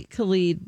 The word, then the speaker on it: Khalid: Camila Cabello, Ty Dolla $ign, Tove Lo, Khalid. Did I say it right? – Say the last one again. – It's probably Khalid. There's DJ Khalid and then there's DJ. Khalid. – Yeah, that's it.